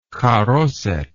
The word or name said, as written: charoseth